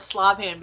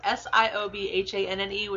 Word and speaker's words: Siobhan: Probably in Oasis call it Slobhand because it looks like that with the font, but it's spelled Siobhanne, which is actually pronounced Siobhan.